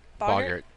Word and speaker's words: Boggart: The Boggart, how do you pronounce it? Bogart? – Bogart, I think. – Boggart. Boggart.